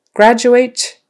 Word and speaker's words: graduate: Graduate. Again, that D in the middle of graduate is pronounced kind of like a J sound.